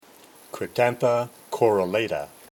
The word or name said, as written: Cryptantha corollata